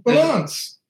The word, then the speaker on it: Vilanch: V-I-L-A-N-C-H. – Yeah, Vilanch. – No. – Vilanch. I'll go fuck yourself. – How do you spell, say chess? It's not cess, it's chess. You have to pronounce the C-H.